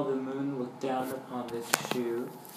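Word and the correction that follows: shew: Show, actually. – Show. – No, it's spelled shoe, but it's pronounced show. – Meanwhile, the moon. – It's like sew, like when you sew.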